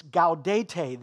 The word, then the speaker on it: Gaudete: I would have pronounced it Gaudete, but it's G-A-U-D-E-T-E, something like that.